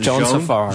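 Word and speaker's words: Joann Sfar: Joan Safar.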